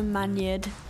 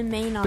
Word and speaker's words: Maynard: Conor Maynard. How do you say it? – Maynards. – Conor Maynard. Conor Maynard. – But it's spelled Man Yard. – Maynard. – No, it's not. Actually, it's spelled Maynard. Okay. – Yeah, Maynard. Like the sweets.